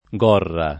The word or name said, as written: Gorra